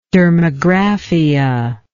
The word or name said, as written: dermographia